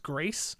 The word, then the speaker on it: Gris: Uh, games for Impact, you had uh, Concrete Jungle, Gris, Kind Words, which is actually pronounced Grease, but everyone calls it uh, everyone calls it.